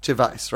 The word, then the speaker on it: Gervais: Gervais, right? And that's how he says it too, right? Yeah. – Oh, yeah. – Justin Hamilton, my great friend Justin Hamilton, always calls him Ricky Gervais.